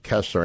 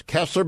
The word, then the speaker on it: Koestler: I believe she is a left, but I don't know, reviewed Michael Scammell's biography of Koestler,